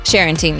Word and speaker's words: Sharenting: Sharenting, yes.